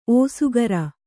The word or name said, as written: ಓಸುಗರ